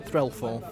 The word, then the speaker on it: Threlfall: Threlfall.